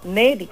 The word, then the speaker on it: Neri: E-R-I. – Perfecto. S. O Meri nomás. Solo Meri. – No, Neri con N.